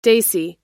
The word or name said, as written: desi